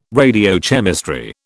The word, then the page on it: radiochemistry: British English